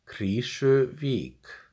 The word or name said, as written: Krýsuvík